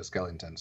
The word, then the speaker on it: Skellington: And for some reason, I think I said Jack Shellington instead of Skellington. So let me correct myself.